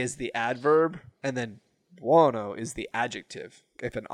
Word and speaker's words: buono: Because he goes, buono. – Buono. – Bueno? – No, he doesn't say bueno. – Buono. – Because I was like, is that how you say it? And then, because bueno is Spanish.